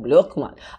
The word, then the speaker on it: Brueckmann: All right, Alex, before we go any further, say your last name in German. – That would be Brookman. – Oh, I like that. Brookman.